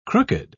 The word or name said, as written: crooked